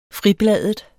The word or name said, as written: fribladet